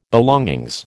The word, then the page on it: belongings: American English